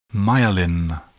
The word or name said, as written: myelin